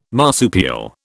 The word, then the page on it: marsupial: British English